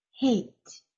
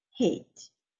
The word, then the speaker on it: hate: Hate, pronuncia bien la H, ¿ya? Hate, odiar.